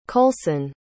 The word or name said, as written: kolson